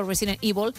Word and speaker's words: Resident Evil: Creo que se están riendo los compañeros de que digo Resident Evil. Me da igual, es cómo se pronuncia. Es verdad que aquí hemos dicho siempre Resident Evil.